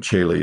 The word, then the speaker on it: Coeli: And Coeli is spelled C-O-E-L-I.